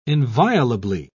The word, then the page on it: inviolably: British English